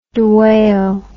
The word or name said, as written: dwale